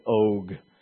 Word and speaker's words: Og: Og.